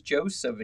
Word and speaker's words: Joseph: If your middle name is Joseph and you're not quite sure how to spell it, it's J-O-S-E-P-H.